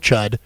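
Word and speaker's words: Chüd: Yes, the ritual of Chud, which, by the way, is spelled C-H-U-umlaut. – Okay. – So it's Ritual of Chud, which I always think is hilarious.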